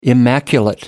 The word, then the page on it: immaculate: American English